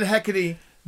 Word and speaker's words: Hecate: Hecate, or is it Hecate? – Well, I've always pronounced it Hecate. They say Hecate.